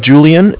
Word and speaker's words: Julian: My name is Julian. It's spelled J-U-L-I-A-N. And I'm calling from Bank of Cardiff. It's spelled C-A-R, D as in dog, I-F-F as in Frank.